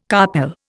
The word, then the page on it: carpel: Indian